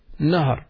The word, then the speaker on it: Nahr: River is Nahar. River, Nahr, Noon Ha Ra.